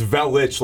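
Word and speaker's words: Velić: It's Velich, like a CH. It's got the little accent on the C in the Croatian language, but.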